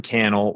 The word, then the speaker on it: Cannell: Sean Cannell across social media. That Sean Cannell rhymes with YouTube channel on the last name. First name, S-E-A-N, last name, C-A-N-N-E-L-L across social media.